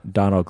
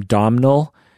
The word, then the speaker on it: Domhnall: I found out you don't call his name Domhnall. – Donald? – It's Donald. Yeah. – Donald. – It's because it's spelled Domhnall. – Yeah, Domhnall. – But apparently it's just Donald. – Donald, I think.